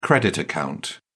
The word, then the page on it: credit account: British English